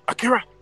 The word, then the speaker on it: Akira: You got to say it like that. Akira.